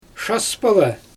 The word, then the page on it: ʃaspalɛ: Xyzyl